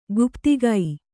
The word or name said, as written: ಗುಪ್ತಕ